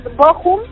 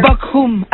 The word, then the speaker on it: Bochum: Buenos días al auditorio, pues sí, saludos, Bochum, así es como lo pronuncian. – Bochum, así saludos sería lo Bochum.